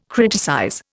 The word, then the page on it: criticize: Indian